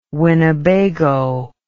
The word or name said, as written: winnebago